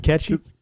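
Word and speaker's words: Cait Sith: And which is another thing, you know, Kate Sith. And then years later, somebody's like, you mean Ketchy? What? You know, it's Welsh. It's Ketchy.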